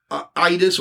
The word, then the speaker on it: Idus: Ides, it's spelled or pronounced Idus or Idus in Latin, I-D-U-S.